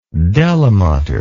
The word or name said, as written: Delamater